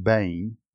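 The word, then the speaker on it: bane (בן): And it's the Hebrew word B-E-N, which is pronounced bane, like we would spell it B-A-N-E.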